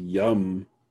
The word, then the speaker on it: Yum: Or Yum Brand where there's a something called Yum. – Yeah, Yum Brand's actually pronounced Yum.